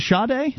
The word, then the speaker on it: Sade: You would have heard Sade. I'm sure you've Sade. Heard Sade. Is it Sade? – No, that's how I've heard it pronounced.